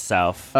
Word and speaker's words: Souf: They spell it with an F. A lot of people get that confused, but yeah, just to clear the air, it's South.